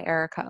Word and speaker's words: Erika: So everything, my website and my Instagram is by Erika Elizabeth, and that's B-Y. And then my first name is spelled with a K instead of an A. So it's E-R-I-K-A.